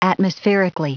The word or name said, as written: atmospherically